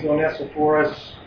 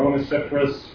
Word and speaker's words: Onesiphorus: And he mentions Onesiphorus, or Onesiphorus, probably pronounce his name, so I pronounce it either way.